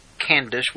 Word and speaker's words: Candace: Candace, we ought typically pronounce it with the soft C.